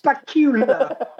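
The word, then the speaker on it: Bakula: We're talking about Scott Bukala, the guest starring in Strange New Worlds. – Bukala? Who's Bukala? – That's how it's pronounced. – Bacula. – Bacula. – Like Dracula. Bacula.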